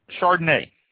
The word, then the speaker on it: Chardonnay: So, to start with here, Chardonnay, spelled C-H-A-R-D-O-N-N-A-Y, pronounced Chardonnay.